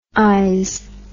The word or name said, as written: ize